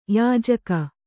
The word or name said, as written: ಯೋಜಕ